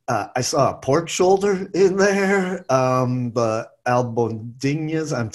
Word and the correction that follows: albóndigas: Albóndigas, the meatballs, yeah, our prepared meatballs, yeah.